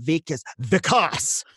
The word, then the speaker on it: Vikas: But Vikas, hey, just, you know, my scene is wrong, but my name is pronounced this way.